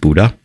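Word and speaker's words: Bodø: So one thing I loved doing was going to the northern part of Norway, to a little town called Buda. It looks like it's spelled B-O-D-O, but with the accent, it's pronounced Buda.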